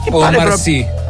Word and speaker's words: Omar Sy: Si pronuncia Omar Sy? Omar Sy. – Andiamo avanti francese.